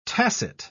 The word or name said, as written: tacit